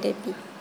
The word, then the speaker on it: Depi: Kalau saya namanya Depi. Panggil saja Depi. – Depi. – Iya. – Devi. Devi. – Depi. – Depi. – Iya. – Ejaannya D-E. – D-E-P-I. – P-I. Depi.